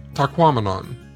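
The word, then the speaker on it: Tahquamenon: But as I learned from a sign within the park, one easy way to get it right is to remember that it rhymes with phenomenon, as in Taquamenon.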